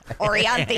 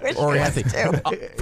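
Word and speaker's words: Orianthi: I don't think I'm saying it right, but. Anthree Offrey. It's the Michael, whatever her name. – Orianthe.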